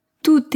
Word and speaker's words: tout: Enfin, tout est prêt. Tout est prêt. Atención. En tu, generalmente no se pronuncia la T del final. Pero si la palabra que sigue comienza con vocal o H muda, sí.